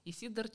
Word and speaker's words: Исидор: Да, это Исидор, ударение все-таки на второй слог, Исидор Чагин.